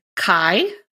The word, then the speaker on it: chi: Like Christ and with Christina Aguilera, the word Christ in Greek begins with the letter chi. Am I pronouncing this correctly, Emily? You corrected me before. – You are, yes.